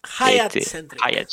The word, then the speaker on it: Hyatt: Sería eh, el, el, evento presencial sería el miércoles 27, como ya dije, de 18.30 a 20.30 en el Hyatt Centric Montevideo, que lo pronuncié bien. – Sí, este, Hayat este, Y acá el señor nos va a una, una, charla.